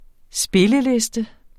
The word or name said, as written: spilleliste